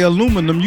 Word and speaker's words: aluminum: We say aluminum. You say aluminium.